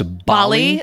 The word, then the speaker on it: Bali: I believe it's pronounced Bali. – Bali. Sorry, Bali.